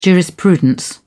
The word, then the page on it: jurisprudence: British English